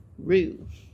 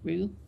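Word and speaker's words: roué: Rue. Is that how you say it? Rue? – Probably not how the French would say it, but that's how I say it. – Mm-hmm. I had R-E-U-E for a long time because 42 across was 12, which turned out to be noon.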